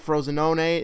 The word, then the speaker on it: Frosinone: He was on loan at Frosinone in Serie A.